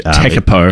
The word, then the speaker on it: Tekapo: And actually, one of the favorite places I've been to in New Zealand was Lake Te Kapu. – Yes. – Which, Tekapo. – Tekapu. Yep. Sorry. Can never pronounce it.